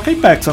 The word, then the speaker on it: K-PAX: É, pelo menos o filme se pronunciava K-Pax, a não ser que eles Às vezes foi dublado, então eu vou falar K-Pax pra você.